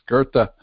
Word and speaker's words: Goethe: It looks like Goethe to me, but it's Goethe. G-O-E-T-H-E. There's no R in there, and yet it's pronounced as if there is an R in there.